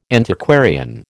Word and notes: Antiquarian: American English

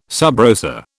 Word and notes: sub rosa: British English

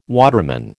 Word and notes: waterman: American English

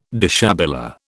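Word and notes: déshabillé: British English

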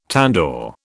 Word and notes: tandoor: British English

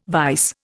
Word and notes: vise: Indian

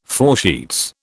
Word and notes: foresheets: British English

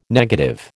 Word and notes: negative: American English

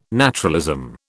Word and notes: naturalism: British English

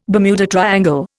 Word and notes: Bermuda triangle: Indian

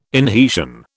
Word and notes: inhesion: British English

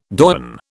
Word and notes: doyen: British English

